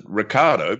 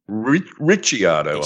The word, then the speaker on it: Ricciardo: I normally pronounce it Ricardo because that's the way he does, but I have been told that it should actually be Ricciardo, I believe.